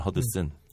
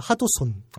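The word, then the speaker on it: Hudson: 이름만 허드슨. – 네. 네. 일본식으로 발음하면 하도손.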